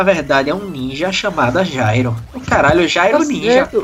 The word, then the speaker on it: Gyro: Mas Jairo na pronúncia. A pronúncia que era G-Y-R-O.